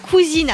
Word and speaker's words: cousin.e.s: Ça s'appelle Cousine. Alors ça s'écrit en fait cousin.e.s parce que cette gamme de produits va s'adresser à un public extrêmement large.